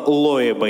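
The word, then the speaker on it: Loeb: Если кто э, э, услышал, да, он говорит, дело Лоеба и компании. Оно так пишется по-английски. Ло, еб. А зачем советскому поэту разбираться в английской транскрипции?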